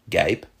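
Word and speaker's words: guêpe: Today, those words are pronounced Gaipe and Gaire.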